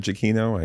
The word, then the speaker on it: Giacchino: See how I'm saying it properly there, Mr. Giacchino? I, I'm finally getting it right.